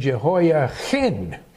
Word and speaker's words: Jehoiachin: And she made it very clear to point out the difference between Jehoiakim and Jehoiachin. And that's the way she pronounced it. She put the real heavy H in there. Because of that German Jew accent. So we learned it Jehoiachin.